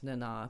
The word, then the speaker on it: Nana: I was also saying, this didn't come up on the podcast so much, but I was not aware that Nana visitor's name is pronounced Nana.